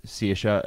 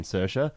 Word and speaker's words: Saoirse: I was saying it was Saoirse, and you were saying Saoirse. – Yeah. – Apparently, it depends on the part of Ireland it's pronounced. Mm-hmm. – Well, there we go. We're both right.